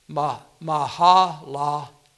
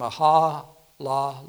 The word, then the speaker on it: Mahalalel: It says in verse 12 of chapter 5, and Cainan, that's the way it's pronounced, Cainan, lived 70 years and begat Mahalalel. That's the way it's pronounced.